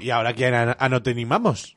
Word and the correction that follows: anatomizamos: ¿Anotizamos? ¿Cómo se dice? ¿Anatonizamos? – Anatomizamos.